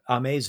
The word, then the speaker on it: Amei: Ame's voice, if you listen, so her name is spelled basically A-M-E-I. Ame, and you look up the song March, M-A-R-C-H, Ame, and listen to a song on, watch on YouTube or whatever.